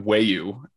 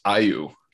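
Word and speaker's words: Ayew: So it's actually not Weyu, it's Ayu.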